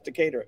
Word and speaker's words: Decatur: That's Decatur.